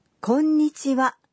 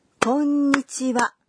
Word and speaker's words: こんにちは: You'd say, こんにちは, clapping to the rhythm of 3 beats. こんにちは.